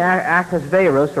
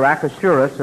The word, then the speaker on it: Ahasuerus: king Ahasuerus, or Ahasuerus, or depending on how you want to pronounce it, asked one day.